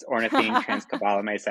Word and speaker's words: ornithine transcarbamylase: It's ornithine transcarbamylase activity.